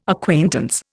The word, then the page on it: acquaintance: Indian